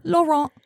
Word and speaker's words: Laurent: It's Laurent with a silent T at the end.